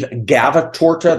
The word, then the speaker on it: Gavatorta: My name is Steve Gavatorta. That's spelled G-A-V-A-T-O-R-T-A.